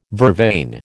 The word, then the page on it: vervain: American English